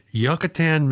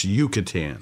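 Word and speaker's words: Yucatán: This woman in Yucatan, Mexico. – Pronounced Yucatan. – Yucatan, I'm sorry, okay.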